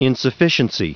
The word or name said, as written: insufficiency